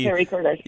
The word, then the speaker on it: pericarditis: Pericarditis? Is it peri or para? Pericarditis? – Yes. – Was peri. – Actually... – Pericarditis.